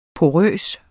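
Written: PORØST

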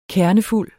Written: kernefuld

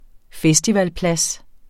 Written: festivalplads

